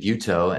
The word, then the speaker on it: Butow: My name is David Butow and it's spelled Butow.